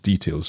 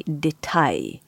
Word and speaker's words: Detail: So I am a Swiss speaker and I like to say Detail. – Detail. – But the Germans, they would say Detail.